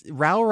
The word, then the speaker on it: Rowell: Rowell rhymes with Al, but I think you just said we were going to talk about Eleanor and Park.